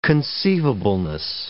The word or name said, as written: conceivableness